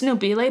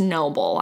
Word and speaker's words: Nobile: And I'm imagining it's Nobile because he's Italian. – Sure. – And it's spelled N-O-B-I-L-E. So I don't think it's noble. I would think it'd be Nobile, right? – Yeah, probably.